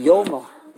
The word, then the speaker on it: Yoma: You say yuma. – Yoma. Yeah. The correct pronunciation is yoma.